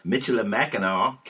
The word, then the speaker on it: Michilimackinac: 1818, Elma area becomes part of the Michilimackinac County. I think I said that right. – That was a mouthful. – It was a mouthful. – That's how I would have said it.